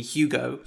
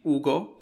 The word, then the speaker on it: Hugo: So in English, people in English call me Hugo. So all my family from England calls me Hugo. But in Italian, it's actually Ugo, because you don't pronounce the H in Italian.